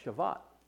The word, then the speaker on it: Shavuot: It's also referred to as the Feast of Weeks, and it's pronounced in the Hebrew as the Feast of Shavuot.